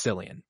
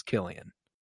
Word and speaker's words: Cillian: A lot of people think it's Cillian, but it is Cillian.